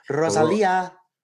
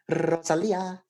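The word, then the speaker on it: Rosalía: I mean, he Rosalia. – I was going to say, can you roll your R's cuz I can't. Rosalia.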